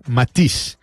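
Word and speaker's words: Matisse: Uno que no es chavo ni conoce toda esta situación, Matiz, se escribe Matice, ¿eh?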